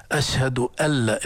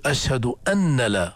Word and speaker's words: أشهد أن لا: علم التجويد علم ادائي، علم لغوي، هي في الاذان اشهد ان لا، لكن هذه النون العرب لا ينطقونها، يدخلون النون الساكنة في اللام وبيسموه هذا إدغام بلا غنة. أشهد ألا، الخطأ هنا ليس خطأ شرعيا لكن خطأ لغوي، تشديد أن، يعني خطأ أن تقول أشهد أن لا خطأ، ولكن تقول أشهد أن، لكن هذه النون ندغمها فصارت أشهد أن لا إله إلا وأشهد أن محمدا.